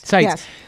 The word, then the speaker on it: Seitz: And let's explain who Seitz is. Am I pronouncing that right? – Yes. – Seitz.